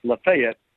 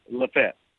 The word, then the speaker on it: Lafayette: And by the way, since I've been here, you and I thought it was pronounced Lafayette. It is not. It's Lafayette. – Stop it. – We've been saying it wrong. – Come on, Lafayette. – That's what the locals tell me, Lafayette.